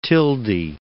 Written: Tildy

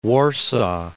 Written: Warsaw

